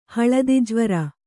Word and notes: ಹಳದಿಜ್ವರ: haḷadi jvara

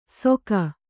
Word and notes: ಸೊಕ: Kannada

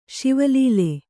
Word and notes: ಶಿವಲೀಲೆ: Kannada

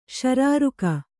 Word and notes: ಶಾರೀರಕ: śarīraka

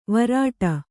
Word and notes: ವ್ರೀಡೆ: Kannada

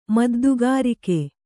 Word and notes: ಮದ್ದುಗಾರಿಕೆ: Kannada

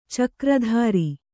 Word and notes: ಚಕ್ರಧರ: cakra dhara

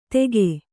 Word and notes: ತೆಗೆ: tege

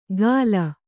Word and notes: ಗಾಲ: Kannada

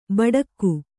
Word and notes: ಬೆಡಗು: beḍagu

